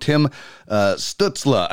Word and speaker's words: Tim Stützle: We're talking about Tim Stützle, and I hope I pronounced that correctly. But for you, Craig, why is he ranked number two in the draft? – Okay, so just quickly, you pronounced it perfectly.